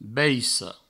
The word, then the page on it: baissa: baisso; Provençal